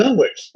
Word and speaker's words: Dunwich: He pronounces it as Dunwich, in the way we pronounce Greenwich Village.